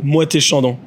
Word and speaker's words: Moët et Chandon: Moët et Chandon. – Moët et Chandon.